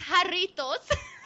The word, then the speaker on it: Jarritos: Matthew Garrett says Jarritos for the win. I assume that is an establishment. – It's Jarritos.